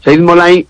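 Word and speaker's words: Saeid Mollaei: como es Said Molay, creo que lo dije bien, ¿no? – Sí, sí, sí, lo has dicho perfectamente. Said Molay es campeón del mundo, subcampeón olímpico, es un judoka iraní.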